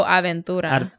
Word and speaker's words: Aventura: I actually been killing this as a Ventura. Is that how you say it? – Oh, Aventura. – Aventura.